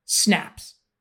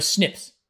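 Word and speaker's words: SNP: They will give you a giant text file containing many thousands of indecipherable SNPs, sometimes phonetically called snaps or snips. Snips or snaps.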